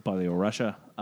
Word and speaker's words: Belarus: Belarusia, if I'm pronouncing that right. – You're saying weird things. It's great. I think it's just Belarus and Belarusian.